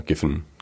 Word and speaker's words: Giffen: Da betrifft es das sogenannte Giffen-Paradox oder spricht man das anders aus? Ich glaube, ja, Giffen.